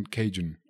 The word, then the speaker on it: Calhoun: Se escribe, es Calhoun, pero muchas personas lo pronuncian Cajun.